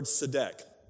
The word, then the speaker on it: tzedek: It's the word tzedek, which is a crazy word to say because it's spelled T-Z-E-D-E-K.